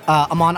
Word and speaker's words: Aman: Aman Ali on Facebook and Twitter. – How do you spell it? – A-M-A-N.